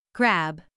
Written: grab

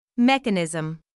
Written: mechanism

